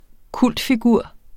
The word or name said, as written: kultfigur